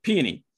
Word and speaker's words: Peony: But without actually checking the deck, I just blindly threw Peony. I always get that name wrong. – Peony.